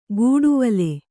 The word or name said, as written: ಗೂಡುವಲೆ